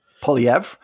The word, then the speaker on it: Poilievre: Because what you're essentially doing is handing the keys to a guy like how do you pronounce his last name? – Polyev. Yeah.